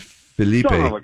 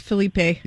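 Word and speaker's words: Phillippe: Ryan Felipe. Wait, did I say that wrong? – I think it's Felipe.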